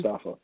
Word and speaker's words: Mustafa: If you know that Mustafa Ali is going to be or Mustafa, I'm sorry, is it Mustafa or Ali? – Mustafa. Yeah.